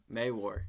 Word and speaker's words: Mawhorr: Mawhorr.